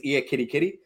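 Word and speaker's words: Ekitiké: I put that into a sound translation because I wonder if it was Ekatike or Ekatike, and they said it was Ear Kitty Kitty.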